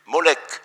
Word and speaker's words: Moloch: Mais je me suis aperçu que juste après le mot roi, vient le nom propre Moloch, qui est d'ailleurs très souvent dans l'Ancien Testament prononcé Molech, justement, puisqu'il n'y avait pas ces voyelles.